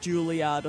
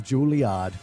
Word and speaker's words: Juilliard: You'd need to get a gig at like Juilliard or something.